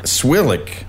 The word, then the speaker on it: Cwiklik: Swilick. Yeah. – It's an interestingly spelled name, C-W-I-K-L-I-K.